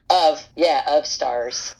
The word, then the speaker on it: Of Stars: They said that it was spilling out the door, so Is that how you pronounce it? Of Stars? – Of Stars. – Okay, so it's not an acronym. – Nope. – No. – Okay. Of Stars. – Of Stars. Like OF? – Like OV.